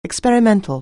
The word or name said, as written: experimental